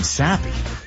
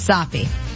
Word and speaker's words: soppy: I thought you kept mispronouncing sappy. – No, not sappy, but soppy.